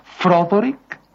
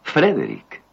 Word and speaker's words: Frederick: ¿Dice usted también Froderick? – No, Frederick. – ¿Y por qué no es Froderick Frankenstein? – ¿Por qué no? Es Frederick Frankenstein.